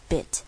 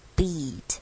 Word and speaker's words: beat: 再来 heartbeat 这个地方容易搞混，容易做不好的是这个 beat， ea 这边也是一个很标准的长母音 e 的组合，所以请不要念成 heartbeat，不要变成了 bit 的 bit，它这边是 beat，是念 beat，嘴型要拉开，要微笑的那个 e。